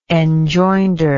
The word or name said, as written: enjoinder